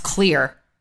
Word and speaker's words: XLEAR: Because the studies that I've read about XLEAR, which is, I believe, pronounced clear. I don't think the X is pronounced the way it is.